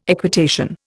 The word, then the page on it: equitation: Indian